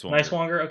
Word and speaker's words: Neiswanger: That's how you can remember how to pronounce his name. Neiswanger. Okay. Nicest Velo Chump.